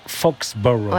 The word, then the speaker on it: Foxborough: Vous le dites vachement bien. – Ouais, Foxborough. – Ouais. Vous êtes d'accord, les garçons? – Je le dis bien.